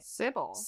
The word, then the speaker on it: Cybele: In ancient Roman legends, there was a consort to the goddess Cybele. I don't know if I'm pronouncing that right. – Cybele. – C-Y-B-E-L-E. Cybele, the goddess, had a consort named Atys, who was born via a virginal birth.